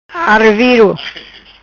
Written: Araviro

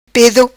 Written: Pedo

